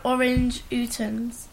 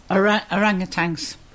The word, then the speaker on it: orangutans: The larger apes are chimpanzees and gorillas from Africa and orange utans. – Orangutans. – Well, it says orange. Okay. – Yeah, that's how you pronounce it. Orangutans. Orangutans. Yeah? – Okay. Orangutans. Mm-hmm.